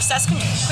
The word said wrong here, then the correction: Sesquicentennial: I have messed this up every single time. I know how to say it. I told you, I sound dumb every Friday at 5 a.m. Congaree National Park or Sesquicentennial? That's it.